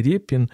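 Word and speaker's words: Рєпін: Е, я все ж таки вимовляю його прізвище Репін, а не Репін.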